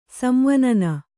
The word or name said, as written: ಸಂವನನ